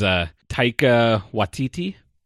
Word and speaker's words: Taika Waititi: Yeah, this is Taika Waititi.